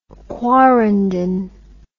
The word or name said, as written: quarrenden